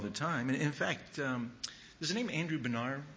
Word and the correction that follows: Bonar: Andrew Benar, Bonner, I think is how you pronounce his name, B-O-N-A-R, Bonner.